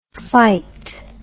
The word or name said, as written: phyt